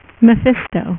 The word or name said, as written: Mephisto